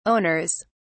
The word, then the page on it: owners: British English